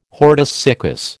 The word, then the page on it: hortus siccus: American English